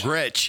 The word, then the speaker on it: Gretsch: How do you say the name of that guitar? Is it Gretsch? – Gretsch, yeah. – It's just Gretsch? – Yeah. Oh, yeah.